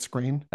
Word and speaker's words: Skrein: Ed Screen. – I think it's Screen, yeah.